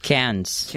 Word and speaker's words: Cairns: Cans.